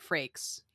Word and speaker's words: Frakes: I'm going to be honest with you, Jonathan Franks. I think this one is fiction. And did I just say Jonathan Franks? I definitely meant Frakes.